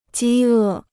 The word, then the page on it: 饥饿: jī è